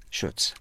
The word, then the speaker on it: Schutz: Dana Schutz, if I'm saying that right. Is it Schutz? – Schutz. – Schutz, okay.